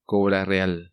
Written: Cobra Real

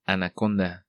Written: Anaconda

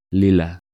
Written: Lila

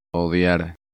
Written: Odiar

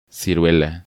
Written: Ciruela

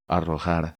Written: Arrojar

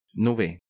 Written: Nube

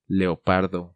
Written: Leopardo

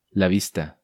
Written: La vista

